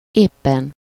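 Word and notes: éppen: /ˈeːpːɛn/; Hungarian

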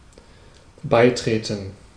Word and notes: beitreten: /ˈbaɪ̯ˌtʁeːtən/; [ˈbaɪ̯ˌtʁeːtn̩]

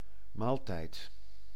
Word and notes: mål: /moːl/

